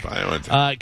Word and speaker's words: BioNTech: Is it BioNTech? – BioNTech.